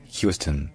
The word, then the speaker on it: Houston: Puis le OU se dit you, you. Et enfin, le stone en fait se dit stone, c'est-à-dire que le O devient un schwa, une voyelle neutre, qui va être à peine prononcée, parce que l'accent tonique est sur la première syllabe. Donc c'est Houston, Houston.